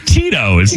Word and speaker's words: Tito's: And in the email, it had the pronunciation of Tito's in case I didn't know. T E E dash T O O S. Tito's.